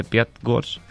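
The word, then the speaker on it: Pyatigorsk: Cogió un autobús hasta la localidad de Piatgors, se dice así. – Sí, muy bien.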